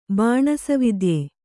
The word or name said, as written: ಬಾಣಸವಿದ್ಯೆ